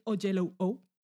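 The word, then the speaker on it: Oyelowo: Que se llama, afortunadamente, he escuchado varias entrevistas de Eva Duvernay estos días, porque si no lo habría pronunciado mal. se llama David O'Jello-O.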